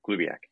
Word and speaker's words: Glubiak: Glubiak.